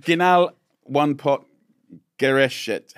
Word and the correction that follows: Gerichte: Yeah, geniale one-pot Gerichte.